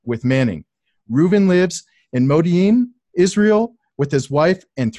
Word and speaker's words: Modi'in: Modi'in.